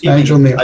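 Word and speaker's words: Kneale: I would guess just Neil. Nigel Neil. – Okay, Nigel Neil. – I'm assuming it's a silent K.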